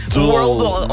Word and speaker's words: Worldle: Worldle, comme le world, comme le monde.